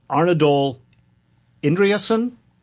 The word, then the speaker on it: Arnaldur Indriðason: One reason I love, I'm a big fan of a murder mystery novelist from Iceland, Arnaldur Indriðason, I think is how you might pronounce it if you want to get close.